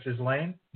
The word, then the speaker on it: Ghislaine: Island, also a silent S, just like Ghislaine.